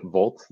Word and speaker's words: VLT: And you can pronounce it Volt, just like, electricity Volt.